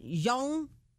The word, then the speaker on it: Jeong: Jeong. – It's J-E-O-N-G. – J-E-O-N-G. – Jeong. – Jeong. Jeong. – How do you say his last name? – Jeong. Jeong.